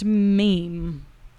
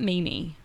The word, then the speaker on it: meme: And finally he was like, that's not how you say that. It's pronounced meme. It's not meme-y.